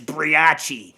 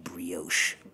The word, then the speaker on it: brioche: Because he was like, what is this briachi? I'm like, it's pronounced brioche.